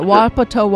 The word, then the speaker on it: Wapato: It's Wapato. – Wapato, Washington.